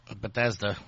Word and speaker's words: Bethesda: And the only thing that did interest me of, how you pronounce this, Bethesda? – Bethesda.